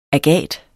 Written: agat

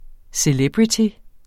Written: CELEBRITY

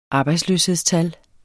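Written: arbejdsløshedstal

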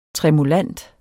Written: tremulant

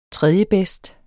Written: tredjebedst

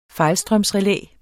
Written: fejlstrømsrelæ

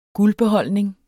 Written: guldbeholdning

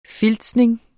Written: filtsning

